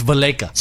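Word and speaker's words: Valaika: It's not Valleca anymore? – No, it's Valleca.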